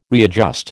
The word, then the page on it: readjust: American English